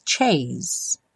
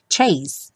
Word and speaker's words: chase: Let the stormy clouds chase. Chase, sem o som do E final.